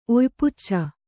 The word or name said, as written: ಒಯ್ಪುಚ್ಚ